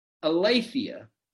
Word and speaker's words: aletheia: If you were to look up the word truth in the Greek, it's pronounced aletheia or aletheia.